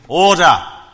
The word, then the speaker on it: Order: Will he have to strain his vocal cords by shouting, "Order! Order!" The ending of which sounds more like a long A or da, rather than ER or duh.